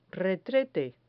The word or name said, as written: retrete